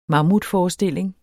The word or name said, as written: mammutforestilling